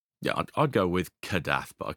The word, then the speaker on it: Kadath: Some places pronounce it Kadath, some pronounce it Kadathath.